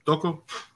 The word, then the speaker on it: Toko: Toko. It's like you pronounce the T as a D.